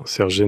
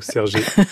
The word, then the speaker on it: sergé: et j'ai mis un accent aigu, puisque vous le prononciez comme ça. – Oui, très bien. Sergé, nom masculin, tissu à côte, oblique.